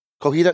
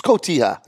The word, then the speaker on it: cotija: It's cotija, right? My wife always makes fun of me. Am I saying it wrong? Cojita? No, it's cotija.